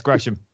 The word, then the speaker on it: Gresham: Always shouted, you're like, it's not Gresham, it's Gresham. – yeah, it's Gresham.